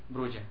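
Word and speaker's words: Brujah: Y la pronunciación es Bruja, ¿no? – Bruja. De hecho creo que es sí, es Bruja.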